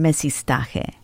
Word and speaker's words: mestizaje: Explain the term mestizaje. – Okay. – Or mestizaje, I was saying it incorrectly.